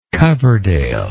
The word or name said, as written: coverdale